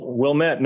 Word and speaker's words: Wilmette: You were raised in, correct me if I'm wrong on this pronunciation, Wilmette? – Well, Wilmette, North Shore of Chicago.